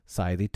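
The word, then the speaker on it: slithy: How to pronounce Scythe Toves? The I in Scythe is long, as in writhe, and Toves is pronounced as to rhyme with groves.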